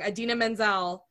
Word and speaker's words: Idina Menzel: I feel like the only reason this movie exploded is because Idina, and I'm going to say her name wrong, Idina Menzel was Elsa.